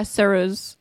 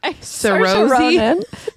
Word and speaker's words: Saoirse: Saoirse. Saoirse, damn it. – Saoirse Ronan.